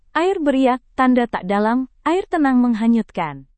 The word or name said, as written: air beriak tanda tak dalam air tenang menghanyutkan